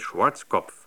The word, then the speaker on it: Schwarzkopf: Inzwischen kennt man ihn überall auf der Welt, den massigen US-General mit dem deutschen Namen Schwarzkopf, der immer so amerikanisch ausgesprochen wird, Schwarzkopf.